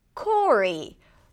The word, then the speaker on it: Corey: Corey is very similar. It rhymes with Rory. A little easier because we don't have an R at the beginning. Start with a K sound, then the OR as in for.